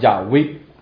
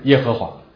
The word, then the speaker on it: 亚威: 当然其实我们知道这个 I am who I am 用这个希伯来文来读出来的时候，这个发音比较会像是叫亚威，而不是耶和华。